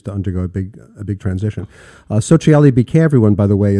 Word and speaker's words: Sociale: Yeah, most of our guests have a hard time to pronounce our name because it's Sociale. You pronounce it perfectly, but the final E is very confusing to a lot of people.